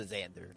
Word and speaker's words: Szandor: Well, it's a Z, that's why. Hard Z. – S-Z-A-N-D-O-R. – Okay. – I thought it was Xander that you pronounced it. I'm not even going to lie to you. It's a Xander.